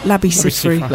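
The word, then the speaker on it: Labi Siffre: Labby Seafra? – Labby Seafra.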